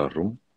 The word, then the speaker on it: Ar-Rum: Yeah, Surah Ar-Rum. – That's how you say it. Surah Ar-Rum.